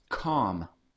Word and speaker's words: Ham: Ham is Strong's Concordance number 2526, and it is pronounced calm.